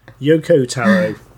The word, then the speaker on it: Yoko Taro: Think I'm really enjoying those Yoki Taro games, y'all. – I was very impressed with your ability to pronounce most of that. – Thank you. – Yoko Taro.